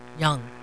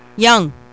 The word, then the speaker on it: Ljung: Her name is Ellen Jo, L-J-U-N-G. – Young. – Young. That's it. Ellen Jo Young.